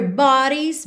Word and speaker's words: bodies: Bodies. Atenção à pronúncia dessa palavra, tá? Porque esse O não é um O de verdade, você não diz bodies, ok? Então, b. E esse D ficou com som de rarara, que é muito comum no inglês americano.